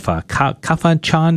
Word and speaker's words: Kafanchan: And actually was ordained a Catholic priest 2004 for the Catholic Diocese of Kafanchan, Nigeria.